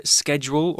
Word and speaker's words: schedule: 嗯。Well, back to the article, and Ryan says, We also discussed my work schedule. It was all pretty casual. Well, the word schedule, or sometimes pronounced schedule mm.